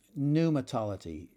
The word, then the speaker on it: pneumatology: Next word, pneumatology. Pneumatology. You spell that P-N-E-U-M-A-T-O-L-O-G-Y. Pneumatology. The P is silent there.